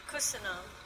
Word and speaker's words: köszönöm: Köszönöm. – Beautiful pronunciation, köszönöm.